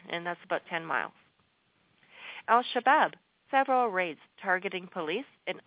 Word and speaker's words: Al-Shabaab: Now, two suspected members of Somalia's Al-Shahab, or actually, if you want the proper pronunciation, I can arrange that for you. Al-Shabaab.